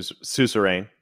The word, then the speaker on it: suzerain: a su suzerain is how I've